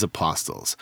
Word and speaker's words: apostles: Then he had to go on a year of mourning because apostles. I've never heard anyone really pronounce the T in that word. – Is it apostles? – Apostles. That's what I've always said.